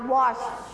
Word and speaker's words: watch: ออกเสียง ch ด้วยนะคะ watch ถ้าเป็น watch เฉยๆมันจะเป็น WHAT